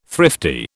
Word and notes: thrifty: British English

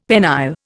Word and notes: penile: Indian

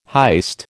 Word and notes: heist: American English

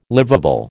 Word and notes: liveable: American English

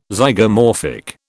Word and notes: Zygomorphic: British English

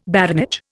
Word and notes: baronage: Indian

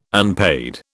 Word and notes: unpaid: British English

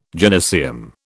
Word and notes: gynaeceum: British English

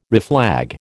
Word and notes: reflag: American English

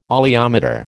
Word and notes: oleometer: American English

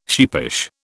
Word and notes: sheepish: British English

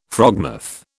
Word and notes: frogmouth: British English

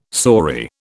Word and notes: saury: British English

